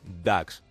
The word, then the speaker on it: Dux: el título de DAX, que se escribe Dux de una de las principales escuelas católicas de Melbourne.